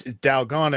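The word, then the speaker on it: Dalgona (달고나): It's actually it's Dalgona as in Dalgona gonna get you. – Dalgona get you. – Dalgona is that Korean treat that we saw in Squid Game that they cut out to not die.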